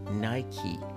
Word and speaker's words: Nike: همونجور که سپلینگ این کلمه نشون میده N-I-K-E هست در انگلیسی این نایکی تلفظ میشه نایکی